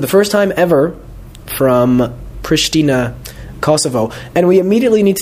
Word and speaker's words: Pristina: I mean, I read English maps and such, and it says Pristina with an S. I understand the pronunciation in this country is Pristina. But then I see, for example, in publications, that people write SH.